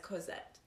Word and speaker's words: Cosette: And I think Julie was the only one who actually said her name right. – Yeah. – That said it as Cosette.